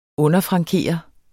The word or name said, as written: underfrankeret